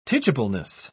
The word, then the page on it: teachableness: British English